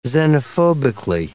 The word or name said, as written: xenophobically